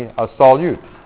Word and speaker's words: solute: Pronounced a little funny, a solute.